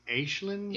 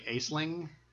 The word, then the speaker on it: Aisling: possibly something with Brendan and it's Aslan. Aslan. – I think it's pronounced Aislin. – Aislin. – But it's Aisling. I think us English yeah. people would say Aisling.